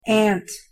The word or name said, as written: aunt